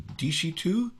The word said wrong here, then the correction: Adishito: Very, very close. There's a little on the front. It's Adishito.